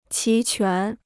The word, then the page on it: 齐全: qí quán